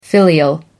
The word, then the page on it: filial: American English